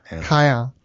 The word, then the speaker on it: Caen: I think it's fun that it's possible that the French city of Cayenne, is that how we pronounce it? – Cayenne. – C-A-E-N may be one of the origins,